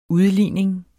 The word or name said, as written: udligning